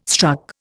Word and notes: struck: Indian

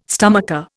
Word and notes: stomacher: Indian

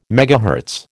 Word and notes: MHz: American English

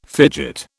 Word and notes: fidget: British English

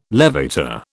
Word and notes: levator: British English

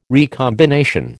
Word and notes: recombination: American English

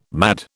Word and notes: mad: British English

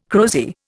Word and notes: crozier: Indian

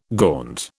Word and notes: gaunt: British English

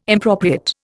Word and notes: impropriate: Indian

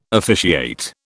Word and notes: officiate: British English

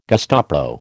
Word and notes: Gestapo: American English

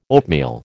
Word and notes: oatmeal: American English

